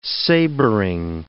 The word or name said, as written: sabring